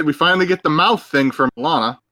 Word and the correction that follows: Melina: From Milena. – For a second. – Melina. – Melina. I said it wrong every time.